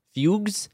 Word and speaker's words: fugue: In some cases, terrifying visions were followed by fugus, which is an old term for, like, fugues. It says fugues. – Fugue. It's pronounced fugue. Yeah, it's like extreme disassociation.